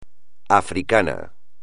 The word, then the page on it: africano: espagnol